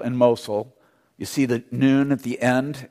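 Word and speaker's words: noon (ن): It's the consonant N, pronounced noon.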